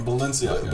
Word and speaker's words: Balenciaga: Am I saying it right? Balenciaga? – Balenciaga? – Like baloney. Balenciaga.